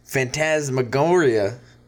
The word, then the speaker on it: Phantasmagoria: It goes to the Prairie Artisan Phantasmagoria.